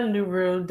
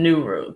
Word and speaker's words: Nuru: Kimya Nuru Dennis. Thank you for asking. So it's pronounced with a long E and then Nuru. Kimya Nuru.